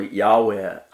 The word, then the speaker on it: Yahweh: So basically, NLT, the original Yahweh, pronounced Yahweh,